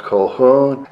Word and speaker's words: Colquhoun: His name is spelled Colquhoun, but it's actually pronounced Colquhoun.